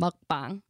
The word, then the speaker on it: mukbang: Is it mukbang? Mukbang? – You said it perfectly the first time. Mukbang.